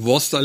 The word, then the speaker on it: Worcester: Bei Worster lässt man die Hälfte vom Wort weg und bei Definés spricht man alles aus, was da steht.